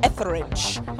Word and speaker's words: Etheridge: hosting the whole thing, and Melissa Etheridge.